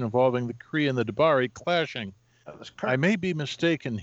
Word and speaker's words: D'Bari: I'm sure the editors at Marvel might have insisted on a change for the X Men's attitude toward the D'Bari. Am I saying that right? – D'Bari. No, that's right. That's right.